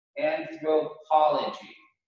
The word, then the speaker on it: anthropology: Anthropology. Five syllables, accent on the po. Anthropology.